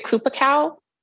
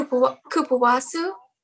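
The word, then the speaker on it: cupuaçu: So one ingredient that I noticed is in a number of your products, help me pronounce this. Is it Kupacao butter? – Kupawasu. – Kupawasu.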